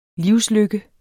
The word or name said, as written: livslykke